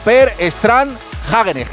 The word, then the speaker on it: Per Strand Hagenes: La ha ganado un noruego, Per Strand Hagenes, que esto se pronuncia así literal, porque he llamado...